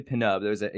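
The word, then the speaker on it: Pnub: And they are Penub, which is played by Eldon Henson, and his name is actually Penub. He said there was a note in the script to pronounce it that way. It's because it's P-N-U-B.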